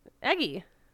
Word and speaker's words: Eguy: I did not know how to pronounce this name until I saw it. E G U Y. Eggy. they were they were calling him Eggy.